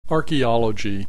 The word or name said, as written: archaeology